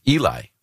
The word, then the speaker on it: Eli: E-L-I, which we would all, and Americans would say, that sounds like Eli, right? And their birth announcement said his name is Eli, which rhymes with jelly.